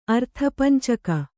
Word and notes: ಅರ್ಥಪಂಚಕ: arthapañcaka

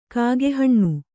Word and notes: ಕಾಕಿಹಣ್ಣು: kākihaṇṇu

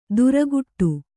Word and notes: ದುರಗುಟ್ಟು: duraguṭṭu

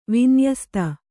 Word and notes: ವಿನ್ಯಸ್ತ: vinyasta

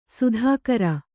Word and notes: ಸೀತ್ಕಾರ: sītkāra

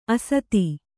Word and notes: ಅಸ್ಥಿ: Kannada

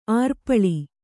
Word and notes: ಅರೆಪಾಲು: arepālu

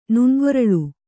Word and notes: ನುಣ್ಗೊರಳ್: nuṇgoraḷ

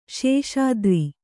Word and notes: ಶಶಿಧರ: śaśi dhara

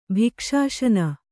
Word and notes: ಭಿಕ್ಷಾಶನ: kannada

